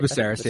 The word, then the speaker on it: Viserys: Viserys, yeah.